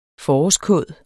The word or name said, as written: forårskåd